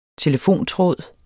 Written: telefontråd

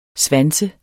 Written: svanset

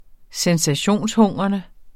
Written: sensationshungrende

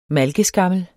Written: malkeskammel

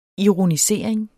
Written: ironisering

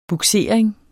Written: bugsering

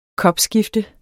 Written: kopskifte

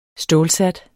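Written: stålsat

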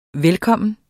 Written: velkommen

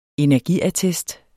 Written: energiattest

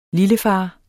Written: lillefar

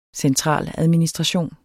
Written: centraladministration

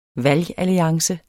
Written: valgalliance

